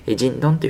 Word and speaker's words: 京: 京都の京に東京の東というふうに書きまして、東京とですね、漢字が全く逆ですね、人ンというふうに読みます。京都の京という字は人というふうに発音しまして、東という字はドンと発音します。